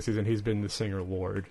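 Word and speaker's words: Lorde: How do you say it? Lord? It's just Lord.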